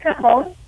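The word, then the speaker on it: cajón: that's a cajon. It's spelled C-A-J-O-N.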